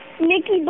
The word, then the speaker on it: Nikki: Nikki Blahitka.